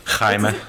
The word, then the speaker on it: Jaime: Jaime. – Jaime is literally like a Spanish name that is spelled like the same way Jamie is spelled.